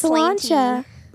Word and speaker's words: Solancha: Everyone says it like that, but it's Solancha.